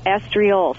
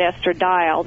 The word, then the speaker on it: estriol: estriol. So I'll spell it for you, E-S-T-R-I-O-L. Do not let your doctor put a D in it and call it estradiol, because that we don't want.